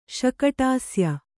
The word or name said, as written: ಶಕಟಾಸ್ಯ